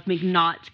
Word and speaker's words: McNaught's: And it reminds me of, okay, this is what reminds me of McNaught's Kingdom of Dreams.